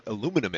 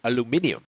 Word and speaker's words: aluminium: And that's why some companies are producing deodorants without aluminum in them. Did I say aluminum in them? Yeah, that's right. I said aluminum in them. Meanwhile, the Brits say aluminium.